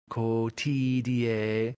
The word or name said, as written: cotidie